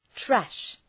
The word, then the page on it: trash: træʃ